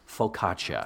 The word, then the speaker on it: focaccia: How do you say that again? – Focaccia.